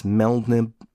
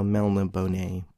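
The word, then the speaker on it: Melniboné: But I've always pronounced it as Melnibone, because there's like an accent over the E. – Yeah. – So Melna Bonet, but I could be Melbourne.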